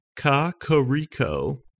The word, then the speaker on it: Kakariko: Purchasing a lovely banana daiquiri to go with your trip to, and then they say personally pronounced Kakariko, which I think is pretty good. Kakariko.